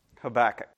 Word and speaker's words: Habakkuk: So in English, it is Habakkuk, to give you the English equivalent of his name in Hebrew. But I'll pronounce it and encourage you to pronounce it in the common English way, which is Habakkuk. Habakkuk. Okay? So that is the pronunciation of the prophet's name.